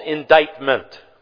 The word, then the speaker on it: indictment: And it's spelled I-N-D-I-C-T. There's a weird C. I-N-D-I-C-T-M-E-N-T. Indictment. That's the way it looks. And that's the way it's properly spelled. And I would spell it I-N-D-I-T-E, indictment. But it's I-N-D-I-C-T-M-E-N-T.